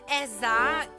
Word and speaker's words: SZA: Lei è Eza, che però si scrive SZA.